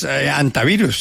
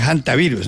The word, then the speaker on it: hantavirus: Pues antavirus, se supone que es, pero hay mucha gente que le dice también hantavirus, ¿no? Entonces, sí. Yo pensaría que es antavirus porque pues el nombre está en español y como todo el mundo sabe en español la H pues no se pronuncia, ¿no?